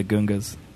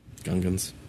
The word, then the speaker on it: Gungans: Like, he didn't even know how to say the name of the alien he made up. – Gungans.